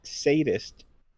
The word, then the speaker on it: sadist: Did you, when Giselle said that she is not something, did you say the word saddest? – Oh, yeah, he did. I just now caught that. – It's sadist.